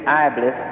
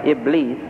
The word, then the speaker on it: Iblis: Now this Iblis, you read it Iblis. The correct pronunciation is Iblis.